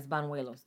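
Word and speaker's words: Bañuelos: It's Banuelos. That's how it's pronounced. That's a Spanish name.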